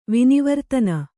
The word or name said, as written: ವಿನಿವರ್ತನ